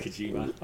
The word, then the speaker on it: Kojima: Yeah, you always say that right. Kojima. – I know. Kojima.